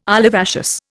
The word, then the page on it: olivaceous: Indian